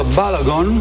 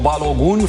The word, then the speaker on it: Balogun: 2-0, Balogun, sau Balogun, fiindcă așa îl pronunță francezii, el joacă la Monaco.